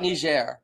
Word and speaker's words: Niger: Anyway, well, speaking of foreign policy, I want to talk about Niger, which a lot of people may have seen it spelled differently, but it is a soft G, not a hard G.